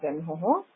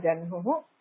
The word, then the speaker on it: जह्नुः: जह्नुः वा जह्नुः वा इति तत् संस्कृते लेखनं हकारोत्तरं नकारः ह्न तत्र उच्चारणे वैशिष्ट्यमस्ति यत्र हकारोत्तरं नकारः मकारः वा भवति तत्र आदौ नकारस्य अथवा मकारस्य उच्चारणं क्रियते ततः हकारस्य